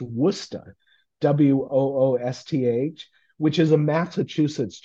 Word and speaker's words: Worcester: So you always have people saying Worcester. I want to go to Worcester. – Yeah. – And it's pronounced Woosta. And my siblings lost it when I told them that I had seen that shirt in the middle of Ghana, you know.